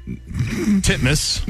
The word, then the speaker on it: Titmus: Pronounce it the way it's spelled. – Ariane Titmouse. Titmus. – There you go. – Is that how you say it? – That is legitimately how you say it.